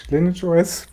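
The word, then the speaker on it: LineageOS: Und bei der Credit Suisse App ist es aber so, die hat, erkennt dann, äh, ich habe jetzt hier so ein Lineage, Lineage OS, wurde ich korrigiert, ich glaube, das wird ausgesprochen, nicht Lineage.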